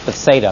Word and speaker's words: Bethsaida: Bethsaida, I'm sorry. You keep mispronouncing it.